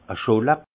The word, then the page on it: e Scholla: Haut-Rhin; Munster